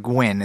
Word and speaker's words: Nguyen: So for listeners who don't know, this woman I don't really know, Tina Nguyen, I think is how you pronounce it. Nguyen? – It's like Nguyen. It's like the most common Vietnamese name.